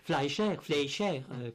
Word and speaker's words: Fleischer: Oui, Fleischer, comme on le prononce en Amérique, c'est le producteur de, pour ceux qui ne sauraient vraiment pas, de Popeye, Mathurin le marin, n'est-ce pas? – Oui, de Betty Boop.